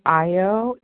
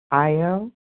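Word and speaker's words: Ayo: My name is I.O. It's spelled A-Y-O, I.O.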